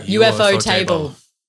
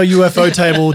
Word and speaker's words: ufotable: I did a whole episode on UFO Table and I was pronouncing it Ufotable. We both were. – We've all been doing that for years. – It took one quick Google search from my partner to realize that it was UFO Table as in like, you know, unidentified flying object because apparently in The Office they have a UFO shaped table.